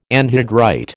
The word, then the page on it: anhydrite: American English